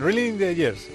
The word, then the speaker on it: Reeling in the Years: Em, Railing in the Years. O Raining. Reeling, Reeling in the Years. Reeling in the Years, sí.